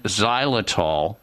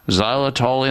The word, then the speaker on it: xylitol: But if your current toothpaste, mouthwash, or mouth rinse or gum or mints, whatever you use, is not rich in xylitol, that's spelled with an X, X Y L I T O L.